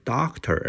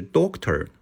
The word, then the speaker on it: doctor: 还有呢，医生是 doctor 而不是 doctor，嗯，want to visit a doctor，doctor 就非常可爱了。